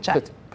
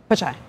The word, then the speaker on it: Pichai: And Alphabet's CEO, Sundar Pichai. Is that how you say it? – Pichai. – But you do the CH. – Pichai, yeah. Sundar Pichai. – It's not a hard CH.